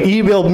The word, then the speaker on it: Emil: Kjell, K-J-A-E-L-L. Is that how you pronounce that? Kjell. Emil Witty. Wait, Emil Minty. Emil Minty. Emil is spelled E-M-I-L. – Emil. – Emil. Emil. It's like emu, but with an I and an L. – Emil. – Emil. Emil. Virginia Hay. Hay is spelled H-E-Y.